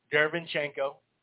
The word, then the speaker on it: Derevyanchenko: How come I can't say his name right? Ever since the V is in there more now, Derevyanchenko, in Adamas.